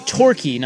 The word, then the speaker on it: Turki: So the guy, the Saudi guy, his name is Torki, not Turkey. It's spelled T-U-R-K-I. – But it's Torki. – You pronounce it Torki.